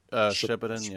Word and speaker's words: Shippuden: Shippuden, yeah.